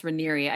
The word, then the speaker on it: Raniere: I thought it was Raniere, but it's pronounced Raniere, I guess.